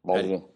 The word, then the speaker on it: Basel: Basel.